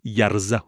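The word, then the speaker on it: يرضه: حفص در آیه و ان تشکروا یرزه لکم، اگر شما سپاس خداوند را به جای بیاورید آن را خداوند برای شما میپسندد، حرکت ضمه ها ضمیر در یرزه را اشباع نکرده است، به طوری که در تلفظ یرزه شنیده شود و این عدم اشباع ضمه ضمیر نیز انحصار به همین مورد دارد.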